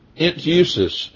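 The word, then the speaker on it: enteuxis: Entusis is the way that's pronounced in the Greek.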